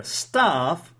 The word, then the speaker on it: staff: However, staff, it's a personnel, it's people who work in a company, it's the employees. Staff, there is a long a sound, okay, like start. Stuff, it's like a cup, okay?